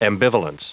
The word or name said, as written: ambivalence